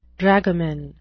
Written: dragoman

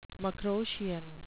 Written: macroscian

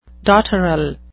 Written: dotterel

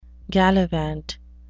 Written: gallivant

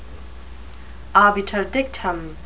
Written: obiter dictum

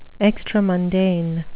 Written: extramundane

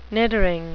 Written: niddering

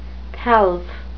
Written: pelf

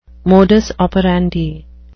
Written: modus operandi

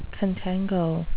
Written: contango

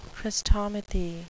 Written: chrestomathy

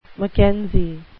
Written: mckenzie

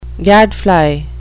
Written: gadfly

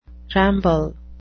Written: ramble

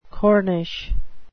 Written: corniche

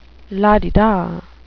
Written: la-di-da

